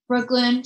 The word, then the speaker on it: Brooklin: Like Brooklyn. She's like, can you spell it for me? I'm like, B-R-O-O-K-L-I-N.